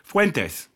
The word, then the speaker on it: Fuentes: Kanye West went on the Alex Jones show with, how are we pronouncing his last name? – Fuentes. Like it's supposed to be pronounced when you're, you know, a Latino immigrant, yeah.